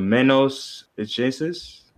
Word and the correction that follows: Manos Ajenas: Manos Ajenas. Yes, it's in Spanish. Manos, and you produced it.